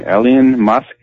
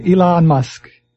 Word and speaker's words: Elon Musk: Elon Musk. Yeah, Elon Musk.